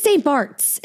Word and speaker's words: St. Bart's: It is St. Bart's. It's how it's said. Like, Bart. Bart Simpson. St. Bart's.